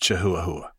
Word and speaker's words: Chihuahua: I don't want to embarrass you, Larry, here in front of everybody, but I'm pretty sure that's pronounced Chihuahua.